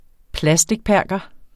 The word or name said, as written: plastikperker